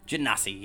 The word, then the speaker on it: Genasi: Genasi, yeah.